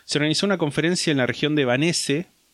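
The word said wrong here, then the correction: Wannsee: Sí, no, no Vanesse, perdón, Vanse,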